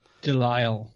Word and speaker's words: DeLisle: Dial? How do you pronounce it? – Delisle.